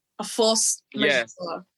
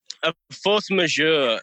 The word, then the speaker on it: A Force Majeure: A force majeure. Yes. – A force majeure.